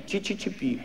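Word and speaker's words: CCCP: Однажды ко мне в Италии подбежал итальянец, восторженный, увидев надпись на майке «СССР» и радостно воскликнул «О, чи-чи-чи-пи, чи-чи-чи-пи», зачирикал так «Чи-чи-чи-пи, чи-чи-чи-пи». Оказалось, по-итальянски «СССР» читается как «Чи-чи-чи-пи».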